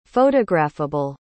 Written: photographable

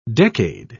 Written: decade

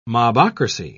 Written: mobocracy

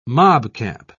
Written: mobcap

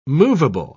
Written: movable

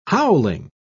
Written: howling

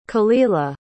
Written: khalilah

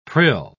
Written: prill

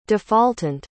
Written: defaultant

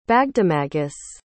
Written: bagdemagus

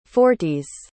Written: forties

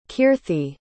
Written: keerthi

